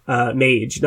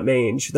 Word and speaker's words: Mage: Mage, not Mange. That's way worse.